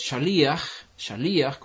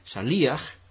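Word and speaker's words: shaliach (שליח): que es shaliach, con el acento en la i, shaliach.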